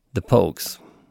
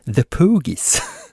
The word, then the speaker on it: The Pogues: Han var ju då sångare i The Pogues. Eller som Jonathan Unge sa i en podd för några dagar sedan, The Poogies.